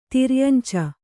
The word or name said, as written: ತಿರ್ಯಂಚ